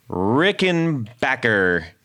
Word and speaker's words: Rickenbacker: Well, Justin, let me tell you, we got a very special 101 because this is Rickenbacker. You said it right.